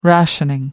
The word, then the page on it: rationing: American English